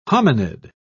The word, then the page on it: hominid: British English